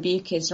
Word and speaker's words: Bukis: And I really do have to mention that Broken Monsters is coming out by our very own Lauren Bukis, rhymes with mucus, in July.